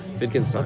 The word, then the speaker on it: Wittgenstein: It's spelled with a W, but it's German, so it's pronounced as a V. Wittgenstein.